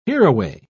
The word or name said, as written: hereaway